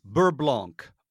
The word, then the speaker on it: beurre blanc: Beurre blanc. Beurre meaning butter. I know that much. Blanc meaning white. White butter? – Well, you don't pronounce the C, buddy. It's beurre blanc.